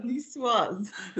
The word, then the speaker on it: Niçoise: Niçoise is what I called. – You're both you do your pronunciation. Go on. – Niçoise.